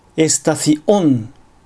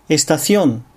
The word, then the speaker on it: Estación: アクセントに気を取られるあまり、アディオス、エスタシオン、シトアード、マジェストオソのように、単語の途中に正門閉鎖を入れてしまう。こういう発音をするとスペイン語としては大変聞き苦しい感じになります。ここは正しく Adios Estación Situado Majestuoso と発音したいところです。